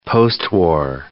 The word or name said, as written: postwar